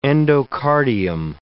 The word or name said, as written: endocardium